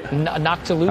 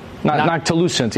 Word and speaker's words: noctilucent: How do you say that? Non-ticulant cloud, right? – Noctilucent. – Non-ticulant cloud? Noctilucent. Yeah. I've mispronounced it like 1,000 times.